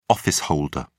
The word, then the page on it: office holder: British English